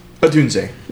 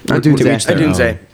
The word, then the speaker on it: Odunze: Odunze. – Odunze.